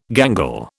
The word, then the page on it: gangle: British English